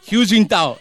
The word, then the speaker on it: Hu Jintao: Hu Jintao, eso es el de China, lo dije bien.